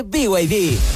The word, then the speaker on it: BYD: ¿Los VIB? – ¿VIB? ¿No es BYD? – Nada que ver. Se escribe BYD, pero se dice BYD.